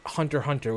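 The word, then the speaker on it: Hunter × Hunter: And you had recommended to watch Hunter Hunter, which I'm assuming is how you pronounce it. Like, you don't say the X.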